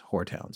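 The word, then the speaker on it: Horetown: Yes, I believe that's the Whoretown Cemetery, which is unfortunately named, but I guess it was named for a family whose last name was Hore, H-O-R-E. – Well, we asked our guest tonight how to pronounce it, and he confirmed that it is Whoretown, so that's where the father is buried.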